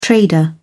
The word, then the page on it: trader: British English